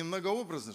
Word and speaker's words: многообразно: Бог многократно и многообразно. Правильно я сказал? Или не многообразно же? Многообразно, да? Многообразно. Да, иногда у нас, знаете, неправильное ударение фиксируется и в привычку входит.